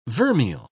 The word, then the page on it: vermeil: British English